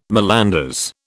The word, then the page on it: malanders: British English